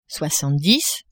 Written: soixante-dix

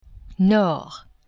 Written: nord

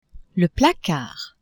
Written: placard

